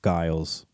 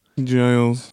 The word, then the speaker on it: Giles: Like how for a while I was saying Farmer Giles for a yeah. – long time. – Yeah. – I was mispronouncing Giles. – Giles.